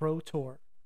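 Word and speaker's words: Rough Tor: Rotor, pronounced Ro to rhyme with bow, and Brown Willie, Brun Winnily, and shine their lanterns in the marshlands.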